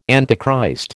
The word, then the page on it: Antichrist: American English